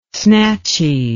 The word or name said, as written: snatchy